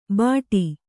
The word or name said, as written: ಬಡಿ